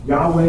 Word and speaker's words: Yahweh: These are the exact same letters that you just saw in Jehovah. Yahweh is the other way to pronounce it. But obviously this one is just Yahweh. So we'll try and vibrate that.